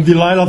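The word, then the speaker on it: Delila: Daarop zeiden zij: die Lila, of wat zeggen we, die Leila, hoe spreek je het uit? Zeggen die Lil of die Leila? Ik ben gewend om die Leila te zeggen, maar ik denk dat die Lila oorspronkelijk de beste benoeming is.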